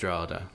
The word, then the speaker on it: Drogheda: So actually pronounced Drogheda. – Drogheda.